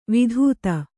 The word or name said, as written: ವಿಧೂತ